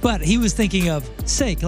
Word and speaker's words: Saak: His name is Brett S-A-A-K, pronounced Sake. – Sake. – Okay, yeah, Sake. – But how do you spell Saki? – S-O-C-K-E-E.